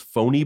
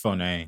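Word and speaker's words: Fone: And I thought like, maybe that's pronounced Phony Bone. Like it's Phoné. – Yeah, but yes. – With the accent? – Yeah.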